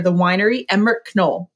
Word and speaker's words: Knoll: The last name is spelled K-N-O-L-L. And many people will pronounce it Knoll, but you actually pronounce the hard K. It's Knoll. And it's one of those things, right?